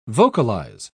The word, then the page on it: vocalise: British English